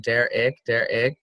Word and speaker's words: Derek: The reason is that it says ick in it, Derek. And like, my name isn't spelled with an I-C-K, right?